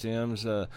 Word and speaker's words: Thames: It's spelled Thames, but I believe it's pronounced Thames.